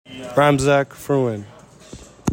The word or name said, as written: RAMZAK FRUEAN